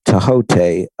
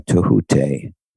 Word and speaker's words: Thoth: R-A-T-A, Ra Ta, and Tahote. It's usually spelled T-H-O-T-H, but it's pronounced Tahote or Tahute.